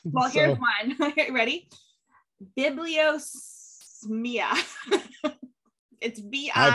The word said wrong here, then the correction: bibliosmia: I feel like you haven't said that right. – B-I-B-L-I-O-S-M-I-A. Bibliosmia. – That sounds more like it.